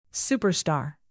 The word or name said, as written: superstar